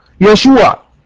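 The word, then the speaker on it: يشوع (ישוע): النقطة رقم اتنين، النقطة رقم اتنين، قلنا ان يسوع في العبرية تنطق يشوع.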